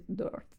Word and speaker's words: Doors: E iniziò lui incidendo singoli come solista, poi, come sappiamo, fondò appunto la band dei Doors, con cui era il Doors. – L'hai detto bene, Doors. Perché ti prendi in giro. È corretto, so.